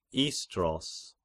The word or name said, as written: Istros